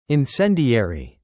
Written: incendiary

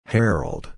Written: herald

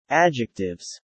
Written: Adjectives

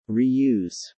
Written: reuse

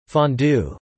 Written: fondue

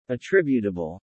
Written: attributable

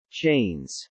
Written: chains

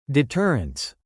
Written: deterrents

